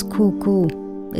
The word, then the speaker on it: Kuku: The name of the island was Kuku. It's spelled K-U-K-U.